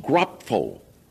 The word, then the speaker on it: grapho: Because in the Greek, there is a special word for something that is written, which is pronounced grapho, from which we get graphic today, you see.